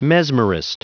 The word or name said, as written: mesmerist